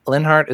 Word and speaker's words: Leonhardt: So Linhart is spelled L-E-O-N-H-A-R-D-T.